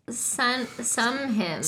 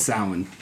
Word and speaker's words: Samhain: I thought it was Samhain, but I forgot, like, the Gaelic pronunciation of a lot of words is totally different than how it's spelled. But it's Samhain.